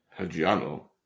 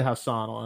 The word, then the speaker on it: Hassanal: The last four digits of his name is A-N-A-L, and I know I'm going to pronounce this wrong. – Hajiano. – No, like it's anal. – All right, Hajiano. – Haji Hassanal. Hassanal? Hassanal? – Haji Hassanal? – Hassanal. I'm going to say Hassanal, and I'm going to be nice.